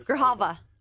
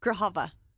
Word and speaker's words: Grijalva: Grava. Say it again, the chairman. – Grijalva. – How do you pronounce it? – Grijalva.